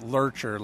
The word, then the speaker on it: Loertscher: It's Mark, M-A-R-K, and then L-O-E-R-T-S-C-H-E-R, and that's pronounced Lurcher, like Lurch of the Addams Family.